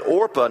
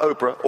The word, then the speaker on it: Orpah: And uh, so Ruth had, had, a, her husband, and uh, that was uh, Naomi's uh, son, and then Orpah, not Oprah, Orpah had her, uh, I said it right, is, is her husband.